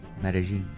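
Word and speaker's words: Medellín: Did I get Medellin right? The no. – second city. – How do you Medellin. – Really? – Yeah. – It's not how it's spelled. – Hear that accent. Say it again.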